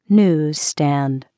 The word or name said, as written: newsstand